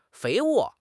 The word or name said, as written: 肥沃